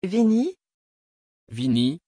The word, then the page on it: vinny: French